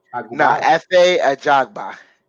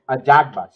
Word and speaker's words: Ajagba: And it was Frank Sanchez versus Ife Agubaya. – Efe Ajagba. – Ajagba.